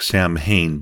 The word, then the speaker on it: Samhain: Now, if you were to read the name, it would seem like it would sound like Samhain, but it wasn't. It's actually pronounced Samhain.